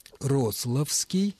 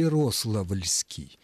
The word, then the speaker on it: Рославский: Другими словами, как правильно «Ярославский» и... или «Ярославльский», «Рославский» или «Рославльский»? Правильны первые варианты. Суффикс «ли» исчезает.